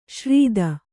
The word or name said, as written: ಶ್ರಿತ